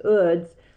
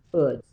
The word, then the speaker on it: Eudes: I say Eudes because that's the French pronunciation. And you know those French, their Yeah. – letters correspond to how that's pronounced because his name is spelled Eudes, but it's pronounced Uds, at least according to pronunciation on Google.